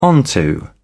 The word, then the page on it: onto: British English